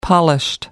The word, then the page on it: polished: American English